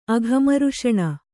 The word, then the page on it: ಅಘಮರ್ಷಣ: aghamarṣaṇa